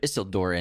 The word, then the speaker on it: Isildur: Yeah, he is nobility because he's the descendant of Isildur, and he's the Isildur. – True king. Isildur, sorry. What do I keep saying? – Isildur. – Isildur. Isildur. – Say it like it's Spanish. Isildur.